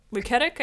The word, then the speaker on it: Luketic: Yes, Robert Luketic, I think is how you, Luketic is how you pronounce his name, but yeah, if you're unfamiliar with Legally Blonde, really owe it to yourself to watch.